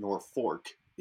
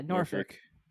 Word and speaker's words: Norfolk: And if they say it, well, it's one of those, like, no, it's not Norfolk, it's not Norfork, it's Norfolk. – Yeah, Norfolk. I mean, for fuck's sake, we have a town and a county here named that, and if you pronounce it wrong, that, like, I mean, okay, we're New Englanders, it's Norfolk. It's Norfolk, properly. Yeah. – Well, yeah, it's like, more derivative of the British pronunciation of Norfolk.